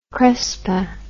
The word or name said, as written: crespin